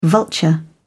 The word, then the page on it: vulture: British English